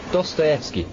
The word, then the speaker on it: Dostoevsky: Dostoevsky, sir.